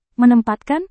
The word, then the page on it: menempatkan: Indonesian